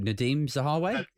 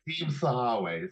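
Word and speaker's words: Nadhim Zahawi: Oh, Nadeem Zahawi? – Nadeem Zahawi, sorry.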